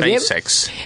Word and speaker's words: SpaceX: SpaceX, dije SpaceX.